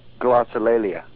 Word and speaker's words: Glossolalia: Glossolalia.